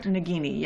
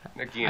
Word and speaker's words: Nagini: Nagini? Am I pronouncing that right? – Nagini, yes. – Nagini.